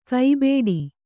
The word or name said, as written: ಕೈಬೇಡಿ